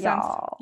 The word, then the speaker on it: Youghal: Youghal spelt Y-O-U-G-H-A-L. – Don't laugh. – I actually had to call up my Irish friend for the pronunciation of it because of the G-H in Youghal. I was very tempted to say, we're going to look at a case from Youghal today.